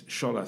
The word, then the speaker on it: Shola: But when you call my name, it's Shola. So a lot of people now put H in front of their Sholas, but if I spell it correctly, it's without a H.